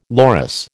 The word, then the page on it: loris: American English